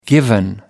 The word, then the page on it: given: American English